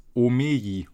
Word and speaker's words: Omeji: Ja, einfach wie Emoji, bloß Omeji, also Omei, Omeji.